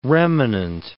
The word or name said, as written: remanent